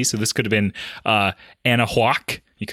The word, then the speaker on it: Anahuac: So this is, you know, this is Anahuac. – Anahuac, yeah. Anahuac, yeah. No, it's not. It's not that at all. It's Anahuac, actually. – Whack spelled H-U-A-C, you know, like the classic whack.